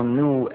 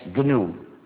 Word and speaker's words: GNU: Pero cuando es el nombre de nuestro sistema, no se debe seguir el diccionario. Pronunciarlo NU es muy confundiente en inglés, sobre todo porque hemos trabajado 21 años, ya no es nuevo, pero todavía es GNU.